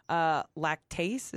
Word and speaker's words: lactase: Oh, no, I was just going to say that basically lactase, is that how you say it? L-A-C-T-A-S-E.